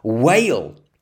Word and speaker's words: whale: Dolphins, seals, a sea lion, and a whale is the word we're looking for. A whale. The spelling is W-H-A-L-E. It's a silent H. Whale.